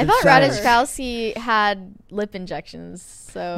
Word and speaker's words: Ratajkowski: Ratajkowski, had the most perfect lips.